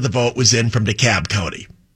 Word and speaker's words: DeKalb: It's pronounced, it's written with an L, but they've all pronounced it DeKalb. It's like the city in Illinois, DeKalb, only they call this one DeKalb. D-E capital K-A-L-B.